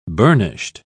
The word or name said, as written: burnished